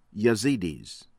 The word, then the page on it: Yazidis: American English; from Iraq